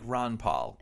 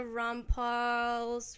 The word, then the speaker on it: Rampal: Because of that's how you pronounce it, I'm like, Ron Paul. – Yeah, it's not. And you know what I keep thinking? And the Rampal's red glare.